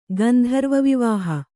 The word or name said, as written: ಗಾಂಧರ್ವವಿವಾಹ